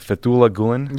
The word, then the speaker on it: Fethullah Gulen: So, Ben, who is, uh, am I saying his name right? Fethullah Gulen? – Yes.